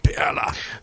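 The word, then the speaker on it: Perle: Actually, pronounced perla in the German. – I totally messed it up. – It's okay. It is the German word for pearl, so you can say pearl.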